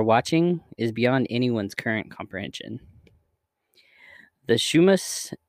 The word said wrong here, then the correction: Chumash: Indians, sorry if I ruined that name, Chumash. Chumash. It's spelled like Chumash.